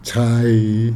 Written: tshai

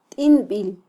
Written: tinbil

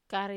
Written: kare

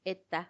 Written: etta

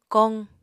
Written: koq

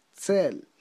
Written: tze'l

